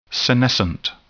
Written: senescent

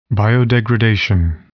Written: biodegradation

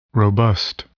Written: robust

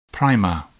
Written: primer

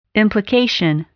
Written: implication

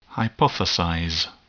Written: hypothesize

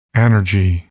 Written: anergy

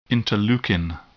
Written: interleukin

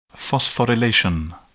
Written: phosphorylation

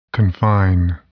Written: confine

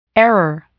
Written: error

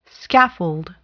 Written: scaffold

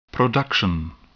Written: production

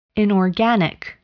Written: inorganic

